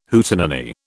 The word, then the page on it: Hootenanny: British English